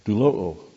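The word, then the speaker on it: Doulo'o (δουλόω): Doulo'o. A lot of O's in there, but this one's a long O.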